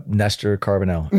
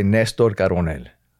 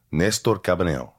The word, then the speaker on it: Néstor Carbonell: My parents would say Néstor Carbonell. – Néstor Carbonell. – Néstor, yeah, Carbonell. There's an accent on the E if you want to throw that out. So Néstor Carbonell.